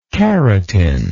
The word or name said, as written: carotin